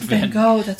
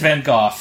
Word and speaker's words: Van Gogh: The worst would be Lust for Life, which is the Kirk Douglas plays Vincent Van Gogh, to be correct. I'm sorry. – Vincent Van Gogh. – That's how you say his name, yes.